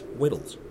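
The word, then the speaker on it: Weddell: At McMurdo Station, the Americans refer to the seals on the sea ice as Weddells, so as to rhyme with petals. I never liked this, as I knew enough about Scottish names to think it should rhyme with Lead Bell.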